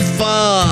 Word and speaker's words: pho: That made me want some faux. Oh, it's pronounced pho.